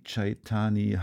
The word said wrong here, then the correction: Chaitanya: Hari. Chaitanya Hari heißt er, Chaitanya Hari.